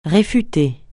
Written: réfuter

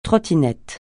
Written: trottinette

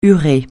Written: urée

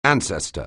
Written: ancestor

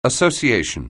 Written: association

